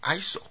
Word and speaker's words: ISIL: 美国总统奥巴马就喜欢用 ISIL，因为它发音比较顺口，合在一起念成 ISO，如果是 ISIS 就念成 ISIS。